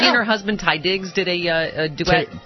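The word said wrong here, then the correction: Taye Diggs: Tay Diggs.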